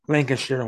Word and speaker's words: Lancashire: I like the way he said Lancashire, which is the way you pronounce it up north.